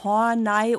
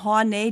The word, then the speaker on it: Horney: Der Angeheiratete war Horney oder Horney, die englische Aussprache, weil wir jetzt hier beides schon hatten.